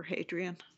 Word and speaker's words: Hadrian: They all start with an address to Little Soul, which I think is a reference back to Hadrian. Is that how you pronounce it? – That's exactly right. Yes, the first century Roman Emperor Hadrian.